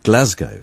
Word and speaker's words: Glasgow: There was this morning on the Today programme when there was some debate about the correct pronunciation of Glasgow, and John had been at an event in Glasgow a couple of weeks earlier and had been told emphatically it should be pronounced Glasgow. So I told him I would look into it. And the BBC's own advice is that it is Glasgow.